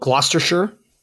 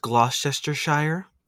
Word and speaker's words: Gloucestershire: Well, so place in England called Gloucestershire. The bomb squad was called... – Actually, it's pronounced Gloucestershire.